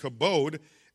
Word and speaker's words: kabod: There's a second Hebrew word that means that's pronounced kabod, and it references the weight of God's presence.